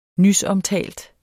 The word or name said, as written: nysomtalt